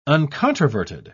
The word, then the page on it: uncontroverted: British English